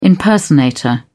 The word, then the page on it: impersonator: British English